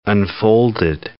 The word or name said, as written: unfolded